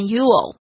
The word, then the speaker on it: you'll: 句子中的 you'll 是 you will 的缩写，念成 you'll，舌头要往上顶发 L 的音。